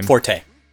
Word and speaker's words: Forte: Forte, or however you pronounce his name. – Forte.